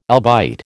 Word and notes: albite: American English

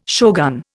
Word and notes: shogun: Indian

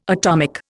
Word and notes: atomic: Indian

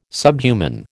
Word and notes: subhuman: American English

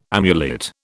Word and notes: amulet: British English

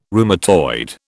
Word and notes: rheumatoid: British English

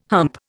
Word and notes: hump: Indian

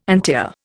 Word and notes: antiar: Indian

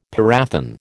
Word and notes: parathion: American English

